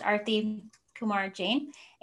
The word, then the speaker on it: Arti Kumar-Jain: So I just wanted to tell everyone, my name is pronounced Arti Kumar-Jain, and I've been working really hard in this period of my life to do a little bit of name correction because...